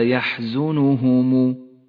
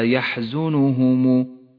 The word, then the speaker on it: يحزنهم: نبدأ بقوله تعالى لا يحزنهم. أولا احذر من اللحن في قراءة هذه الكلمة، إذ أن حرف الياء حرف مفتوح وليس مضموما، لا يحزنهم، وإياك أن تقول لا يحزنهم فإن ذلك لحن جلي.